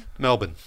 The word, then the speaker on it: Melbourne: Melbourne. – Yeah, you don't... – You don't put the R in there. – Yeah, that's right. It's Melbourne. Right.